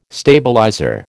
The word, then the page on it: stabilizer: American English